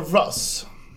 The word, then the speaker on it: russ: Have you heard about the Russ? – The who? – The Russ. Russ. We pronounce it Rus.